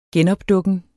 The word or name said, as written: genopdukken